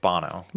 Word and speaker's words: Bono: Bono and The Edge. You're like, oh. – I believe it's pronounced Bono.